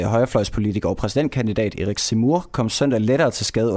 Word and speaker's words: Zemmour: Jamen, vi skal nemlig høre om øh, den øh, franske præsidentkandidat øh, Erik Zemmour. Zemmour, jeg håber, jeg udtaler det.